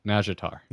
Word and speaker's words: Nazjatar: And Nazjatar. – Just think of it as like a soft J, like Nazjatar.